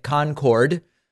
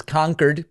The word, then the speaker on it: Concord: Trump pronouncing it Concord, like the infamous supersonic jet, but it is Concord.